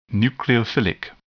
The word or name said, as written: nucleophilic